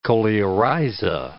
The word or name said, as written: coleorhiza